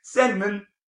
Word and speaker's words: Sandman: The word sand and man. You put them together. Now put them together. – Sandman.